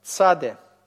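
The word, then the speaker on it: Цаде (צדי): Да, конечный вариант, цаде. Произносится как «ц».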